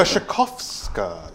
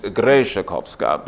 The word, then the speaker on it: Grzeszkowska: Grzeszkowska. Yes. – Okay. – Grzeszkowska. – Very good. I was putting the emphasis on the first syllable, but Grzeszkowska.